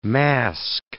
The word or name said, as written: mask